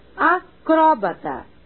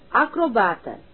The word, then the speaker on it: acrobata: Os seguintes vocábulos admitem dupla tonicidade: acróbata ou acrobata, biótipo ou biotipo, hieróglifo ou hieroglifo, oceânia ou oceania, xerox ou xerox.